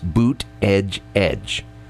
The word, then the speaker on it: Buttigieg: If you remember one point in time, yeah, we had the people come out and say, now here's how you pronounce his name. Think of a boot and then put an edge and an edge behind it. So it's boot, edge, edge.